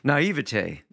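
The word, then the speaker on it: naïveté: Is that how you pronounce it? Naivete. There you go.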